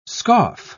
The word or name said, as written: scoff